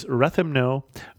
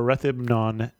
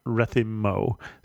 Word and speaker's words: Rethymno: It is Rethymno or Rethymnon, not Rethymmo. So you are pronouncing it correctly.